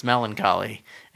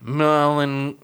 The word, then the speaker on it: melancholy: Gilroy goes like melancholia or something. It's like, does he try to say melancholia? What does he say? – No, it's not close. – Melancholy.